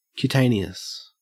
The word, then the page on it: cutaneous: Australian English